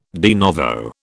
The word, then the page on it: de novo: British English